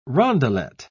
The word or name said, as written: rondelet